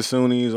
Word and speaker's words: SUNY: The Coonies, the SUNYs, all that stuff. – Yeah, I never know how to pronounce that. – Yeah, so I don't. So it's like CUNY.